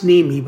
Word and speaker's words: Niemi: Pronounced Neamey, but it's spelled N-I-E-M-I.